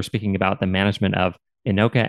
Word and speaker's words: INOCA: In the English language, we say INOCA and MINOCA.